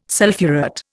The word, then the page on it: sulphurate: Indian